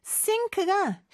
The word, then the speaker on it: sink: S하고 시옷의 발음에 차이가 있어요. So sink, S 발음은 좀 더 입 앞에서 나는 소리라는 거, 요것도 기억해 주세요.